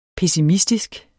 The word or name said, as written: pessimistisk